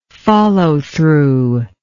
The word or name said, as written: follow through